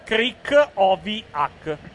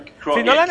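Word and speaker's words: Krychowiak: Ecco, Krikoviak invece è Gregors Krikoviak. Questa è la pronuncia come l'hanno scritto. – Krikoviak. Però in Inghilterra dicono Krikoviak.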